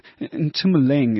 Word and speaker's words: Tumuleng: I know Tumi, but Tumuleng, is that correct? Am I saying it right? – Yeah, Tumuleng is the obviously the long version, but you know the short version.